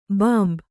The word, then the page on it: ಭಂಭಾ: bhambhā